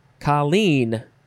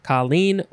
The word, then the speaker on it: colleen: And our last word is colleen. You could emphasize either syllable, colleen or colleen. C-O-L-L-E-E-N, noun from 1828, and it just means an Irish girl.